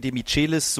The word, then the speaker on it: Demichelis: Martin Demichelis, so will er selber ausgesprochen werden, obwohl es ihm eigentlich relativ egal ist, aber das ist die spanische Aussprache seines argentinischen Namens.